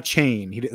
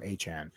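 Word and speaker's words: Achane: It's not 4chan. Not 4chan. – It's not Chain. Everybody's been saying Achan. He wants it Achan. So I'll say it correctly for him. Achan.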